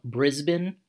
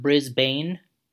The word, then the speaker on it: Brisbane: So we're going to kick things off with a group called Kentucky Green, who are from Brisbane, Australia, not Brisbane, as most uh, as most uh, Americans would probably pronounce it, as my friend and former colleague Martin would tell me that he was from Nottingham and not Nottingham.